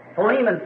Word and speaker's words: Philemon: So, Philemon, someone said back there, which I think is really the correct pronunciation of it.